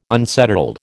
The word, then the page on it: unsettled: American English